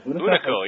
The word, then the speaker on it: Hunico: Hunica? How do you pronounce it? – Hunico, yeah. Hunico Rana.